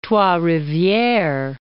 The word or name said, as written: Trois-Rivieres